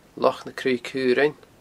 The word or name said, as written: Loch na Craoibhe Caorainn